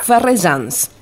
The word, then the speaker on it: Ferrejans: ¿Es Ferretian su apellido, Lorenzo? – No, Ferrejans, y con J. – Ferrejans, perfecto.